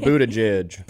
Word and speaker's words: Buttigieg: Pete Buttigieg, how do you say his last name? – Buttigieg. – Buttigieg? – Pretty sure on that.